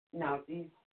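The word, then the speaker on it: Nauthiz: It's actually spelled Nauvhiz. – Mm-hmm. – Okay. But it's pronounced Nowthese. – Nowthese.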